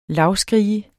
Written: lavskrige

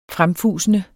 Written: fremfusende

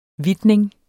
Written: hvidtning

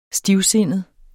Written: stivsindet

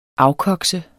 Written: afkokse

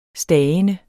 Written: stagende

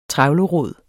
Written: trevlerod

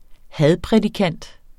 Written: hadprædikant

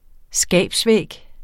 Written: skabsvæg

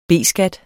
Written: B-skat

